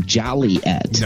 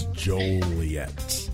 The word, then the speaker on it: Joliet: Yes, and it should not be pronounced Joliet. – No, it is not Joliet. It is Joliet.